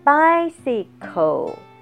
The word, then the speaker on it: bicycle: 所以在 bicycle 它一共发音有三段高音在第一段第一个 C 是发的是 S 的音第二个 C 发的是 K 的音 bicycle 脚踏车或自行车跟 bike 一模一样的意思 because 因为高音在第二段 OK drops He drops the box.